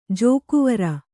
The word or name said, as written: ಜೋಕೊವರ